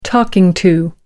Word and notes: talking to: American English

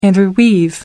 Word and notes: interweave: American English

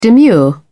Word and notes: demure: British English

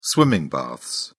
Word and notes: swimming baths: British English

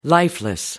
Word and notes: lifeless: American English

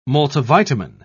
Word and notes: multivitamin: British English

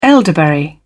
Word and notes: elderberry: British English

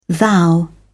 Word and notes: thou: British English

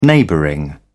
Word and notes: neighbouring: British English

